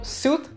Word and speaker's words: suit: Так вот, suit — это костюм. И читается оно как suit. Не suit, не suite. A suit.